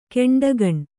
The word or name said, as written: ಕೆಂಡಗಣ್ಣ